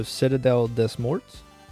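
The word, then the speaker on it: Citadelle des Morts: C-I-T-A-D-E-L-L-E. Des Morts. So Citadel Des Morts? – Yeah. – Yes? That's how you say it?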